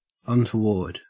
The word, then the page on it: untoward: Australian English